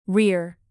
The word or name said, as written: rear